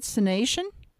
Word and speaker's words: Cynation: Oh, so that's how you say it, Cynation? – Yeah, that's right.